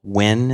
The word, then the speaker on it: Nguyen: I guess Bill Nguyen is how you pronounce it. It's spelled Nguyen or something.